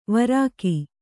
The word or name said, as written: ವಾರಕ